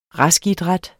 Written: raskidræt

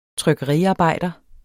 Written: trykkeriarbejder

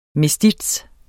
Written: mestiz

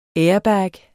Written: airbag

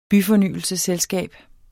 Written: byfornyelsesselskab

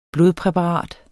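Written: blodpræparat

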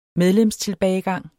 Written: medlemstilbagegang

